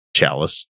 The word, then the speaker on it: Shalice: The Tribune, a media partner of the TV station, spoke to Shalice Blythe, which was interesting to hear everybody mispronounce it. Chalice. – Yeah. It is spelled like Chalice.